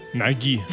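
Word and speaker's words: Nagy: Che, come si pronuncia Nagi correttamente? – Naghi.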